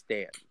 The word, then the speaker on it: stan: This is coming from a Chance the Rapper stain. Anyways. – Oh, it's you see now already your sentence is invalid because you said stain and it's pronounced Stan.